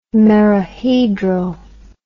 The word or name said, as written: merohedral